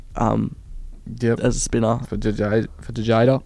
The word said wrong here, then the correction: Jadeja: um, and Jadeja. – There yeah, go. Yeah, sorry, that was that was right pronunciation.